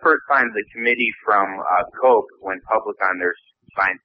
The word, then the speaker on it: Koch: And they've received a ton of money from this organization called, it's pronounced Koch. – Yeah, it's spelled K-O-C-H, but it's pronounced Koch.